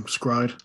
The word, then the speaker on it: s-CRY-ed: Oh, my favorite anime of all time, Scryd.